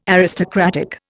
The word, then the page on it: aristocratic: Indian